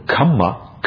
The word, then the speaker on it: kamma: There's a slight different in pronunciation that is often missed by people who don't have an Indian background. And the kamma is a double M, and it's pronounced as if kamma, twice. Kama is a long A sound, and it's different.